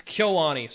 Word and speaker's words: KyoAni: I remember being in Kyoto when Kyoto Annie caught on fire. It was scary. – You say the name wrong every time. – It's Kyoani. Sorry.